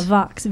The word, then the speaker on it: Voxx: Yeah, Vox. V-O-X-X, as they say, neither X is silent.